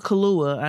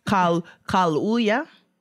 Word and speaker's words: Kaluuya: Daniel Kaluuya, I know that is not how you say his name. – Oh, okay. Yes. – Kaluuya? – Yep.